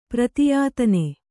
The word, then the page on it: ಪ್ರತಿಯಾತನ: Kannada